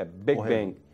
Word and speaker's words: Big Bang: É, Big Bang.